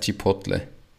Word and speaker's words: Chipotle: Wie spricht man das aus auf Deutsch? Das heißt Chipotle.